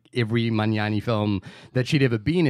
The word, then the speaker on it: Magnani: Magnani, sorry, mm-hmm.